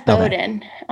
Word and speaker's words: Bowdoin: Is that how you pronounce it? Bowdoin? – Yeah, it's Bowdoin.